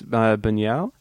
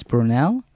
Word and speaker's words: Buñuel: By I don't know how to say his name, Louis Buñuel. – Louis Brunel? – Well, there's not an R, but yeah.